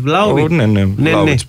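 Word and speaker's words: Vlahović: Είναι ο Βλάοβιτς η Μπεσίχτας. Από την Κιουβέτσα. – Βλάχοβιτς. – Βλάοβιτς προφέρεται.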